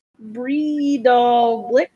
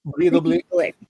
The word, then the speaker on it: Breidablik: Breidablik. – Breidablik. There you go. – Breidablik.